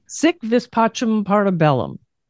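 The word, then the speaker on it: Si vis pacem, para bellum: Sic vis pacem, parta bellum. – Correct. Okay. Right on. Good pronunciation too.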